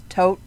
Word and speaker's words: tot: The word for dead or death in German is tot, T-O-T, and it's pronounced T-O-H-T, at least I think so, and that's the dude from Raiders of the Lost Ark, the dude who gets his face burned off.